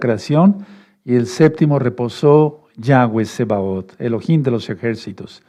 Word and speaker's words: Yahweh: La letra Bav sonaba como W mucho antes, o sea, en el paleo hebreo. Y ese es el hebreo que vamos a hablar cuando venga Yahshua. Por eso el nombre correcto es Yahweh. Yahweh.